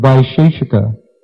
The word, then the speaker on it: Vaisheshika: Furthermore, we're not done yet, there are other theoreticians, namely the proponents of Nyaya and Vaisheshika. Now here we can look at the Sanskrit here. Vaisheshika. Vaisheshika. Ka. There's no she in this word. There's no long I.